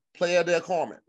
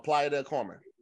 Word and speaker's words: Playa del Carmen: I feel like you're saying that wrong. Okay. – I knew I said in the state. – You said Playa del Carmen. – I said Playa at first. You're right. I fucked it up.